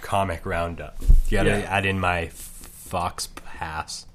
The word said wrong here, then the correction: faux pas: I was saying them phonetically. Fauks pass? I don't know. – Faux pas. That's what you're trying to say. Faux yeah, but I was saying it phonetically. Are you shocked that I know what phonetically means? – Except that phonetically it's pronounced faux pas. – Yeah, but... – Phonetically it would be F-O-H-P-A-H. Faux pas.